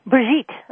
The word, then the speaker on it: Brigitte: It's Brigitte, right? Like sweet. – Brigitte.